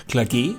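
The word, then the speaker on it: Clage: Klage spricht man mit langem E wie Effizienz.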